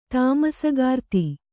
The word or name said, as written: ತಾಮಸಗಾರ್ತಿ